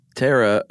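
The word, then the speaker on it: Ptera: Yeah, these Terra starts with a P, of course, a silent P.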